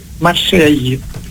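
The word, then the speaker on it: Marseille: Στα γαλλικά προφέρεται Μαρσέγη. Ναι, ακούγεται ναι. Το γη τέλος πάντων. – Ναι, και δεν ακούγεται, δεν προφέρεται το τελευταίο γράμμα, αλλά που, ναι, τι θέλουμε λοιπόν, να πούμε τώρα.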